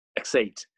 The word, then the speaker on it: X8: Which is the appropriate way to pronounce it for some reason. – X8. Yeah. – They didn't want it to be 18. They wanted it to be X8.